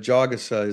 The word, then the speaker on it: Jagusa: But Jagusa is the correct pronunciation, and so is Traore. – Let's go back to the offensive line. Kali 1-2-3.